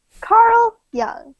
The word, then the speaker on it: Carl Jung: Carl Jung.